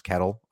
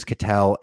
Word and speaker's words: Ketel: Real quick on Ketel Marte, who I pronounce kettle, as an educated human. It is Ketel.